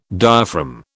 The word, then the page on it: diaphragm: British English